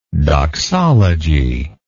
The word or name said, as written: doxologically